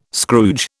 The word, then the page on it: Scrooge: British English